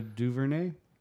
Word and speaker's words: DuVernay: Ava Davi. How do you pronounce that name? – Hold on. I got to find. Ava DuVernay.